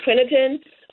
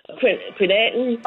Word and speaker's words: creatinine: How do you say it? Crinitin? Creatinine.